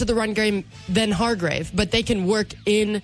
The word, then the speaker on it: Hargrave: Hargrave? – Yep.